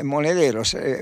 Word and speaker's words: Monedero: Ah, ese me equivoqué, lo pronuncié mal, Monederos, fue Juan Carlos Monedero quien aludió a esto.